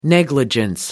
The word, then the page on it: negligence: American English